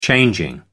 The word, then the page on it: changing: American English